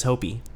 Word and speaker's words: Hoppe: Hoppe. I say Hop, you say Hoppe, Henry says Hopi. We got it right, one of us. It's probably you. – Well, yeah, because I've heard him say his name.